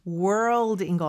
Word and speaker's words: world: 오늘의 미션 문장에서 가장 어려운 단어는 발음하기가 world인 것 같아요. – 네. – RL 발음하는 거 earl, earl 요 발음 많이 연습해 주시면 됩니다.